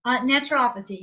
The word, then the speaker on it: naturopathy: Am I saying that right? – Naturopathy, yes. – Naturopathy, thank you.